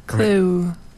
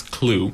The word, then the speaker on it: Clough: But this Clue Club, which is spelled stupidly, even though it's spelled C-L-O-U-G-H. – Clue. Right? – Clue. – But it is Clue, right? – Yes, it's Clue.